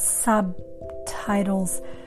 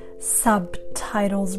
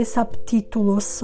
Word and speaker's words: subtitles: Practice the pronunciation of that word, subtitles. Subtitles, right? You don't want to say subtitulos or something like that. No, it's subtitles.